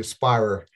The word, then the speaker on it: Speyrer: Speyrer. It's German.